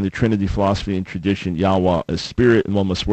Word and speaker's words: Yahweh: He said, I personally believe the correct pronunciation is Yahweh.